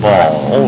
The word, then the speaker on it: Baal: We write it in English, B-A-A-L, and usually pronounce it Baal.